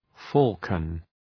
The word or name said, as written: falcon